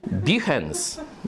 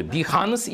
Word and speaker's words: Behance: Behance. Ja nie jestem artystą, ale jak? – Behance. – Behance, bo tu dwie wymowy. Behance i Behance. No i się gubię.